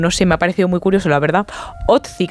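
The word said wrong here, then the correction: Ötzi: creo que se llamaba, un nombre muy, muy noruego. – Utzi, ¿no? – Utzi, pues sí, Utzi.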